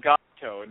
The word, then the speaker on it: Gopto: But the book is called Gopto, and it's spelled G-O-P-T-O.